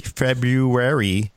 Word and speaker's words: February: I said it correctly, February 6th, 2018.